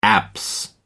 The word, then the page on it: apse: American English